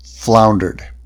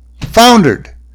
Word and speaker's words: foundered: The gale, however, increased to so violent a degree that the number of the vessels foundered. Foundered! That's how the word's pronounced. Foundered!